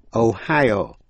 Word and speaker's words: Ohio: Tiểu bang Ohio, nhấn mạnh vào vần nhì, nhưng Idaho, I-D-A-H-O, nhấn mạnh vào vần đầu, Ohio, Ohio, Idaho. Colorado, C-O-L-O-R-A-D-O, Colorado.